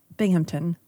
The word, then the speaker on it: Binghamton: So I'm assuming that's because the good people of Binghamton, there's no P.